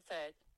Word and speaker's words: Pheart: Pronounce the word P-H-E-A-R-T. – Okay, I found this on the web for pronounce the word furt. Check it out. – Furt. – Furt. – Furt. – Okay, my mind did not go there. – No. Well, because E-A-R-T would be ert.